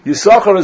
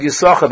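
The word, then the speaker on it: Yissachar: In the word Yisachar, there's a silent sin. One sin is pronounced and one is not.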